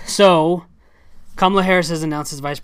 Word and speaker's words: Kamala: He's talking about her and he keeps saying Kamala, and it's pronounced Kamala, like Kamala. But he keeps calling it her Kamala.